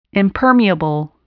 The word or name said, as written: impermeable